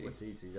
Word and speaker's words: Taika Waititi: Taika Waititi. – Waititi director, yeah.